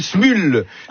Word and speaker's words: Smule: Ça s'appelle Smule. Hein, Smule, ça s'écrit S-M-U-L-E, avec plusieurs centaines de millions de téléchargements.